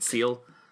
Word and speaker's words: CL: And what I really like about CL, which, by the way, I just realized I have been pronouncing their name wrong this whole time. I've been saying CL. Is it Seal? – I've always said Seal, yeah.